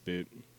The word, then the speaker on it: Boutte: It's not Booty, it's Boutte.